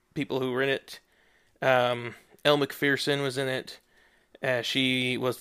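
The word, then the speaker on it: Elle Macpherson: And since she's Australian, I wanted Danny to say her name for us with his Australian accent. – What's your name? – Elle McPherson. – You mean Elle McPherson? – Yes, yep. Thank you.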